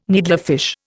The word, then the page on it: needlefish: Indian